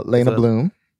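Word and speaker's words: Leyna Bloom: We have Lena, am I saying that right? – Lena Bloom.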